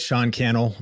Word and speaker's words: Sean Cannell: Yeah, I mean, you said it, Sean Cannell, rhymes with YouTube channel, at Sean Cannell on Instagram.